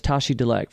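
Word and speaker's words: Tashi Delek: And the common greeting amongst Buddhist people there is Tashi Dilek, four syllables.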